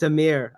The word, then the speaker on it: Amir: Amar, say your name for me, because I'm probably saying it wrong. – You're good, Joe. It's Amir. Amir Agoora.